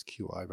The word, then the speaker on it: qi: that was kind of thrown together was the one that's CHI, uh, but in the more modern, I don't know, scholarly version, it's QI, but it's pronounced exactly the same.